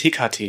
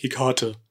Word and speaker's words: Hekate: Übrigens Hekate, ja, nicht Hekate. Ah. Das ist mit der griechischen Aussprache. Ich habe jahrelang immer Hekate gesagt.